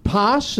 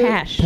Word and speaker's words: Pash: As Ellen, as Bliss's friend, Posh, is that how you pronounce it? – Pash. Like passion, yeah.